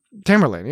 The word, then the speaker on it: Tamerlane: Is it like Tamerlane? – Tamerlane, yeah. Where Tamerlane, whatever the correct pronunciation is.